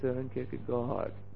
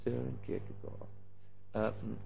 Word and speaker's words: Søren Kierkegaard: There is this philosopher, you will have to say the name again, Søren Kierkegaard. Yes, does he say it properly? So, Søren Kierkegaard, you don't spell the D in the end.